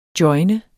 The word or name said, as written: joine